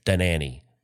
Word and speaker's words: Dhanani: D-H-A-N-A-N-I. Danani, probably the most reasonable way to pronounce it.